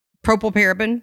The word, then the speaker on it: propylparaben: And when I was researching for this podcast, propylparaben, how do you say that one? – Propylparaben. – Propylparaben. Mm-hmm.